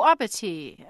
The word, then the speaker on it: abatis: And that's spelled A-B-A-T-I-S. So that's following the French rules of spelling. – A-B-A-T-I-S. Oh, abattu. Well, that I can see you silent S.